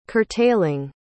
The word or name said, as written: curtailing